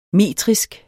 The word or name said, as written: metrisk